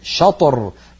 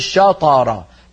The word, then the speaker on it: شطر: شطر شطر شا نفتح الفم عند الشين شا، أما الطاء فنباعد فقط مخرج الطاء ولا يبتعد الفكان شطر، ولما ننطق الراء نباعد بين الفكين شطر شطر، ولا نقول شطر، لا نجعل الفكين يبتعدان عند قلقلتنا لحرف الطاء.